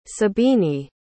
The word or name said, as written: sabiny